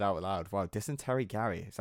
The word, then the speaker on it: Dysentery Gary: Because we're talking Dumpweed, we're talking Don't Leave Me, we're talking Aliens Exist, we're talking What's My Age Again, we're talking, I never had how to pronounce it, Dicentary Girl? – It's called Dysentery Gary. Is what it's called.